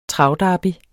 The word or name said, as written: travderby